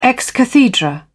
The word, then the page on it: ex cathedra: British English